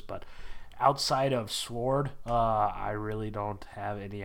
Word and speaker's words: S.W.O.R.D.: How do you say it? Sword? Yeah. Sword? Sword? – Yeah. – Sword? – Yeah, I don't pronounce the W at all.